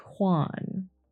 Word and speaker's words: Juan: I think this is Juan. That's how you pronounce it.